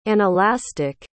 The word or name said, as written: anelastic